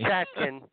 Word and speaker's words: Schatkin: Schatkin.